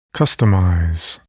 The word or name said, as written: customize